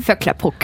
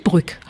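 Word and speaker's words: Vöcklabruck: Vöcklerbruck. – Vöcklerbruck. – Vöcklerbruck, genau. – Ach, nicht Brück. – Nein, Bruck.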